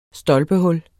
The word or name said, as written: stolpehul